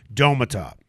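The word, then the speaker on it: Domata: Is it Demata or D- uh, Domatop? – There we go. Domatop.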